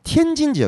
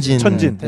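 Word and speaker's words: 텐진 (天津): 이제 베이징이 있고 그다음에 중국 내 하나의 또 직할시인 텐진 지역이 있어요. 텐진. 텐진이라고도 합니다. 천진. 네, 맞습니다. 네, 네. 천진 오히려 좀 귀 익으실 거예요.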